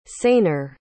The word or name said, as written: saner